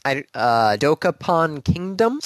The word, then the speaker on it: Dokapon Kingdom: Was Dokapon Kingdom? – Yes.